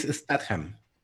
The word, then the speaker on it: Statham: La que viene es una parecida que se llama Cash Truck, que es con Jason Statham, Lynne Renee, Josh Hartnett. – A ver, yo quiero resolver esa duda de una vez por todas. – Ajá, ¿qué cuál duda? – Es Statham.